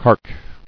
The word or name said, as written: Cark